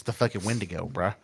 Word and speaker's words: Wendigo: Like they do it with a supernatural twist of the father became a Wendigo. – Wendigo, bro. It's a Wendigo.